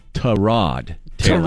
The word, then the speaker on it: Tyrod Taylor: And it said, note that from here on out, the proper pronunciation of our quarterback's name is Tyrod Taylor.